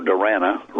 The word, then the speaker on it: Durana: Okay, and then way on the right side of this photo with the blue and white bannered shirt, that's Joe Durana, rhymes with banana.